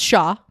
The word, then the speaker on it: ZZXJOANW: ZZXJOANW, pronounced Shaw, Maori, 1, drum, 2, fife, 3, conclusion.